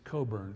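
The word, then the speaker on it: Cockburn: He's kind of a quirky folk singer, Bruce Coburn is the way he pronounces it.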